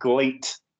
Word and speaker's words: Gleet: for those of you who didn't hear last week's show, it is called Gleet. It's pronounced great, but with an L, because it's owned by Ledet Entertainment, hence why the L's in it.